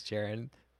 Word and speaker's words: Jaron: This, actually, I was thinking about the title earlier today, but my name is Jared. Pronounced, said like Jared, but with O-N instead of E-D.